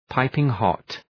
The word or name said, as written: piping hot